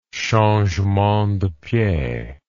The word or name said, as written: changement de pied